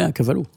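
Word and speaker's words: A Cavallo: Est-ce que je le dis bien, à Cavallo? Ouais, à Cavallo.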